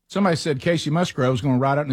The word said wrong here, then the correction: Musgraves: He's Musgrave. – Hey, you got to call her Musgrave. – I got the name right. That was a great line. Musgraves? – Musgraves, yes.